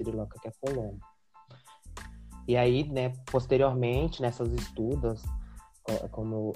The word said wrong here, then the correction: estupas: eles chamavam, eu não sei se pronuncia estudas mesmo, é estupas, né, quer dizer.